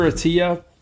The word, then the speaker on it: Attia: It's Peter Attia, and his last name is spelled A-T-T-I-A, so it's Peter Attia.